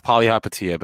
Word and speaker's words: Palihapitiya: Close enough. Chamath Palihapitiya, but you were I was oh, I forget I forget a couple couple uh syllables in the in the last name.